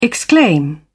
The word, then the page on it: exclaim: British English